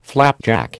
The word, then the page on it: flapjack: American English